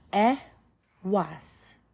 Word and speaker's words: Ehwaz: And then the next one is Ehwaz. Phonetic value is e. Pronunciation e was.